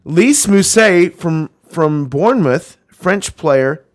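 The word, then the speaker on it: Lys: Lice or Liss? – Liss. – It's L-Y-S, Brian.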